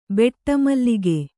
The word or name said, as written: ಬಟ್ಟಮಲ್ಲಿಗೆ